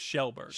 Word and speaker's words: Kjellberg: Kjellberg. – That's actually very good. – Really? – It's just Shellberg. – Shellberg. – Yeah, it's an SH.